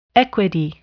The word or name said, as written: equity